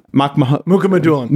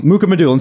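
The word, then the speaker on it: Mukhamadullin: Shakir Makhmalhuddin, Mukamadulin. – Mukamadulin, excuse me.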